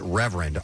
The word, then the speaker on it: RVRND: And he's using a moniker, which is pronounced Reverend. R-V-R-N-D is how that's spelled, all capital letters.